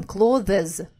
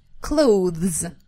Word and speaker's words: clothes: Все обычно произносят следующим образом «clothes». Вот это «э», которое появляется между «з» и «з», нам совершенно не нужна. Должно быть «clothes».